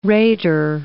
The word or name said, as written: rater